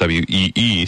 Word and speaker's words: Wii: Instead, Satoru Iwata says the Nintendo Wii, and that's spelled W-I-I, but pronounced W-E-E, systems will try to reach the hearts and minds of those who aren't gamers by nature.